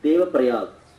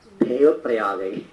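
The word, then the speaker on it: Devaprayag: So, later on, this place became Deva Prayag, to be very simple. But if you are Tamilian, then you will pronounce as Deva Prayagai. So, all N, I, all comes at the end.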